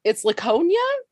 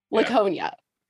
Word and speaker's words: Laconia: It's Laconia. – Yeah. – Laconia. – Yeah. – Yeah, is what they said. We finally have a pronunciation for this planet.